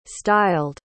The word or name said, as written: styled